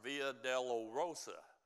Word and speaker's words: Via Dolorosa: They call that march Via Dolorosa. Did I say that right, Carol? I said that right.